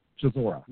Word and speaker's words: Chisora: How do you pronounce Chisora. – His last name? – Chisora. – Yeah, he's going to fight this week. – Yeah, Derek Chisora.